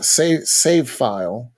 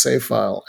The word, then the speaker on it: Savephile: So that's save file. Which is spelled SAVEPHILE. Yes. So it's save file.